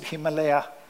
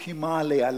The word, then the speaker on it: Himalaya: I know you guys pronounce it Himalaya. We forgive you because we mispronounce a lot of English words. so you can mispronounce one Indian word, but if you want to pronounce it right, you have to pronounce it Himalaya, long A, because it has a meaning. Himam is snow, alayam with a long A is house, house of snow.